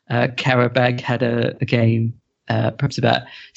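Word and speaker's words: Qarabağ: You've got Karabag right, but it's Azeri, not Azerbaijani.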